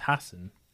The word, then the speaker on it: Hasan: Is it Hasan? – No, it's Hasan.